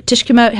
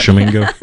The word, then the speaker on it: Tishomingo: Uh, Tisho, Tishkomo, how you say it? Tishomingo, Tishomingo Park, State Park. Tishomingo.